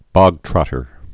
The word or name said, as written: bogtrotter